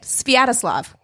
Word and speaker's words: Sviatoslav: That's how you say it, Sviatoslav.